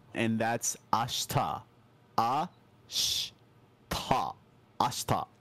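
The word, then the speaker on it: ashta (明日): Yeah, did you all notice that, like, he kind of didn't really pronounce the she there? It's like, instead of ashita, it's ashta. Like, there's this thing in Japanese called high-vowel devoicing, and that's the whole reason why we don't really say the E sound there.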